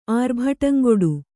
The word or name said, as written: ಆರ್ಭಟಂಗೊಡು